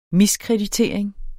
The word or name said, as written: miskreditering